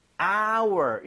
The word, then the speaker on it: hour: H는 자음이면 어잖아요. – 하지만 묵음이죠. – 그죠. 이게 hour 묵음이 아니라 네. hour 이렇게 발음이 되는 거예요. 발음 자체가 모음 사운드예요.